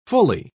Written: fully

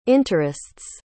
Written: interests

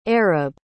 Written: arib

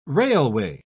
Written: railway

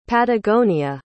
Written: patagonia

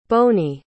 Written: Bonie

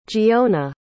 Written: giona

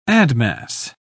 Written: admass

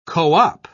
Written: co-op

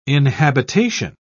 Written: inhabitation

